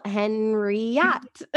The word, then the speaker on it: Henriot: My last name is Henriot. And a lot of people say it wrong. And I'm like, it's just like it's spelled Henriot.